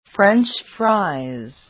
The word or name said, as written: french fries